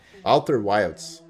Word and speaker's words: Outer Wilds: E um jogo que entrou agora recentemente, Older Wilds, Wilds, não sei como é que se pronuncia. Outer, Wilds. – Isso, Alter Wilds. Outer Wilds, exatamente. Joga esse jogo.